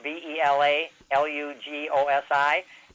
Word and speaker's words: Bela Lugosi: Or I never knew that the correct pronunciation for B E L A L U G O S I is Bela Lugosi.